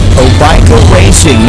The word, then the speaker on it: Obaika: Obica Racing. Now, if you look it up, it's spelled O-B-A-I-K-A Racing.